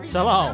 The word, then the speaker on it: Talal: Talal. – That's how you say my name in Arabic. Yeah, okay. Let us say it again. – Talal. – It's got a hard T on it.